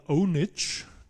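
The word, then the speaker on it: pwnage: Das spricht man Ownage, also das P vorne ist ein O.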